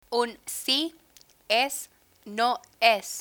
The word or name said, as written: un si es no es